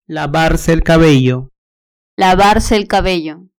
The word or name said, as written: lavarse el cabello